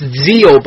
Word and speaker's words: Zexal: Like, it's Zeal, but it's spelled Zexal.